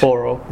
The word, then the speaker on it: Auro: Now, certainly we don't want to give away too much of the plot, but what made you decide to call the city A-U-R-O, pronounced? – Oro.